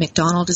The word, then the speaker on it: McDonald: And McDonald is spelled M-C-D-O-N-A-L-D.